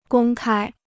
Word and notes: 公开: gōng kāi

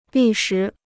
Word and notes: 历时: lì shí

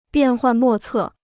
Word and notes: 变幻莫测: biàn huàn mò cè

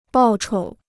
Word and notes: 报酬: bào chou

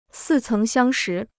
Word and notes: 似曾相识: sì céng xiāng shí

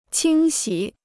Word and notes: 清洗: qīng xǐ

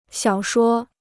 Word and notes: 小说: xiǎo shuō

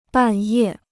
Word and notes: 半夜: bàn yè

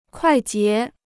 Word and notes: 快捷: kuài jié

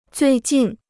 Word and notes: 最近: zuì jìn

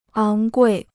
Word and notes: 昂贵: áng guì

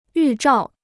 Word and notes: 预兆: yù zhào

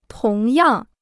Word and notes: 同样: tóng yàng

